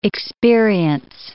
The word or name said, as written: experience